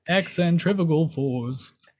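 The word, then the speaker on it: Excentrifugal Forz: Cause I mean, there's only nine songs on the album and we're going on to song number six, Excentrifugal Fours. I've heard the song too many times to not get that correct. – Oh yeah, I had to listen to the song to know how to say it.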